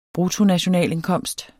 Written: bruttonationalindkomst

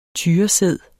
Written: tyresæd

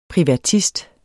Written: privatist

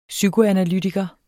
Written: psykoanalytiker

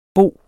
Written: boer

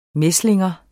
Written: mæslinger

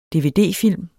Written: dvd-film

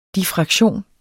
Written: diffraktion

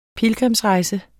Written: PILGRIMSREJSER

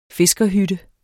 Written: fiskerhytte

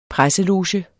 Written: PRESSELOGE

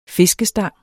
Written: fiskestang

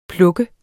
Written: pluk

